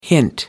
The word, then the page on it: hint: American English